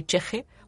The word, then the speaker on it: ch'ixi: La, lo cheje es una palabra aymara que en realidad está escrita como CH, comilla, IXI, o sea, pero se dice así cheje,